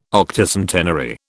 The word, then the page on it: Octocentenary: British English